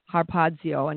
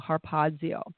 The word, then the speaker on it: harpazo: He wrote in Greek, and the word he used was harpazio, and it's spelled H-A-R-P-A-Z-O, harpazio, and it means to seize, carry off by force, to seize on.